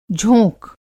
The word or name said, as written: झोंक